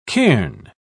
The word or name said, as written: cairn